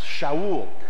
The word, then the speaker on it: Shaul: All right, anyway, so that's how his name would have been pronounced, Shaul.